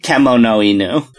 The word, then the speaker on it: kemonoinu: All of the, everything in Japanese is pronounced just directly, like there's no variations based on how you combine things, it's just kemonoinu.